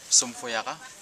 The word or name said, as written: somfoyaga